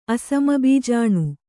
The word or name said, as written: ಅಸಮಬೀಜಾಣು